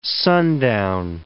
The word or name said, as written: sundown